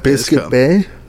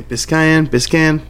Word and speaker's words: Biscayne: Whatever that Biscuit is Bay? – Not Biscuit Bay. Biscayne, whatever.